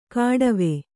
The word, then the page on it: ಕಾಡವೆ: Kannada